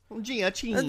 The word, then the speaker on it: Giacchino: I did some research on the tunes, and the guy who does the score was Michael Giacchino. I think his name is pronounced Giacchino.